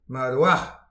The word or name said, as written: mat a-walch